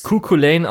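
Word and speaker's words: Cú Chulainn: Also ich weiß, das wird, glaube ich, Cuculain ausgesprochen und es wird auch ungefähr so geschrieben. Und es ist natürlich auch hier irische Mythologie und darum, das wird halt Skahach ausgesprochen.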